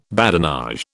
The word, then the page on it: badinage: British English